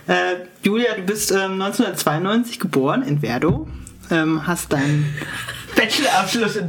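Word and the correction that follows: Werdohl: Wie spricht man das richtig aus? Verdol. – Verdol? – Ja. Oh Gott, dann habe ich dein L vergessen.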